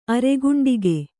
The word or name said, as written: ಅರಗಂಡುಗ